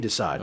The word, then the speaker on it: de Sade: Marquis de Sade.